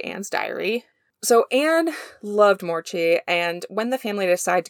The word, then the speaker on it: Moortje: So, while Mauchi brought me to this topic, I would like to start by talking about Anne Frank's own cat, who went by the name Morchi. And it's spelled M-O-O-R-T-J-E.